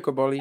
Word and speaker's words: Cobolli: Flavio Coboli.